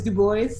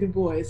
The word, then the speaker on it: Du Bois: And I'm even saying his name wrong, it's Du Bois. He doesn't pronounce it the French way. – That's a conversation for another time. – But yeah. he pronounces it Du Bois, so I should say it how he says it.